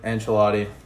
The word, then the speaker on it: Ancelotti: Ancelotti.